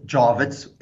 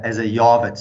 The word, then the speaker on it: Jawitz: It's pronounced Jawitz, as you pronounce it. Most people say that the J as a Jawitz. So you're one of the few interviewers who've got it right.